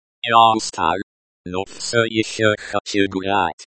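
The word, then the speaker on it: ещё: Еще.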